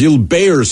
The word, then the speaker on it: Gilbert: It looks like Gilbert is pronounced Zilbeyer syndrome.